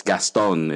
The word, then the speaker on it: Gaston: And I like the way you pronounced my name, very French. Though here in the Philippines, it's phonetically, it's just Gaston.